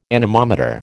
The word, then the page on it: anemometer: American English